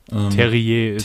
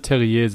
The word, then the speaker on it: Terrier: Terrier. Als Franzose, es müsste Terrier sein.